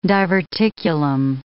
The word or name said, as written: diverticulum